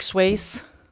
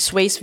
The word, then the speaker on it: Swayze: Jamen, vi var på udkig efter uh, Patrick Swayze. Patrick, Swayze. Patrick Swayze. Der var lidt uenighed om, hvordan man udtaler efternavnet, Sway, men vi ved Swayze alle, hvem han er.